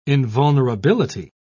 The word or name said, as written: invulnerability